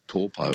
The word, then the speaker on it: Taupo: Now it's called Taupo. – Taupo, yeah, that's right. It's just the vowel pronunciations are a bit different to, they don't really have that ow sound, like cow, meow.